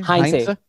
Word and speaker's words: Heinze: So you Gabriel do Heinze. Pronounce the H in this in Spanish, although it's not supposedly most of the time you don't, but with him you do. It's Heinze.